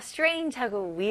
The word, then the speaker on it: strange: 네, 여기서는 여기 G 발음을 J로 하셔야 됩니다. 그래서 strange, strange. 그리고 J로 마무리 하셔야 됩니다. 마지막에 으 소리 나오시면 안 돼요. 그래서 strange, strange 이렇게 하시면 안 되고요. J로 끝나셔야 돼요.